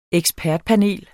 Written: ekspertpanel

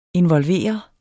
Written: involverede

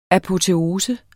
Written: apoteose

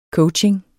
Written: coaching

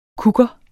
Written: kukker

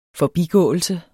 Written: forbigåelse

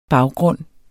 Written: baggrund

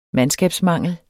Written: MANDSKABSMANGEL

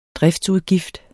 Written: driftsudgift

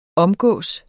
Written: OMGIKKES

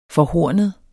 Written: forhornet

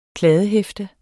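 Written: kladdehæfte

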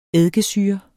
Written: eddikesyre

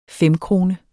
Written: femkrone

